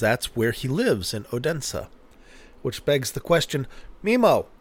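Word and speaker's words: Odense: And I was corrected by two non-Americans on the pronunciation of Odensa, and it is in fact Odensa, and our fair Mimo, patron extraordinaire, patron prime, I think, patron patronus primus.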